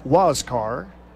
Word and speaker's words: Huascar: Name is spelled H-U-A-S-C-A-R, pronounced Waskar. And then Enoa, Y-N-O-A.